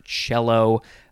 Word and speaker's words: cello: Next is a fun word because it is not pronounced how you think it should be pronounced. It is the word cello, but it is spelled C-E-L-L-O.